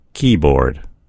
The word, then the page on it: keyboard: American English